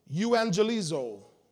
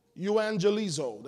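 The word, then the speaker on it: Euangelizo (εὐαγγελίζω): Euangelizo. Euangelizo. That's how you pronounce it. Euangelizo.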